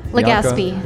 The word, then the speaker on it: Legazpi: Legaspi.